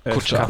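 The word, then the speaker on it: Kutcher: berühmten Investoren an Bord haben, nämlich Ashton, wie wird er ausgesprochen? Kutscher. Kutscher.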